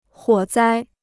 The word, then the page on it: 火灾: huǒ zāi